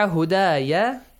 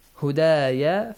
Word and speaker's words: هدايا: So فمن تبع هدايا, it's not هدا. هدايا فلا خوف تنوين ضمة plus عين.